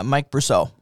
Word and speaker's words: Brusso: Is it Brasso or Brusso? I don't know how to say it. – Mike Brusso. – Brusso. Okay.